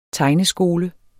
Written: tegneskole

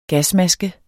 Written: Gasmaske

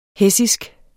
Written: hessisk

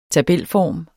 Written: tabelform

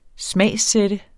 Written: smagssætte

